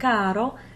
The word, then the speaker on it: caro: Just think of how doubling the R in the word caro in Italian changes the meaning from dear to carro, crazy.